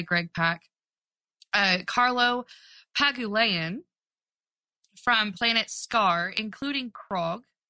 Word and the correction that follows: Korg: Korg. – Fun fact, Korg. See, I always say his name wrong.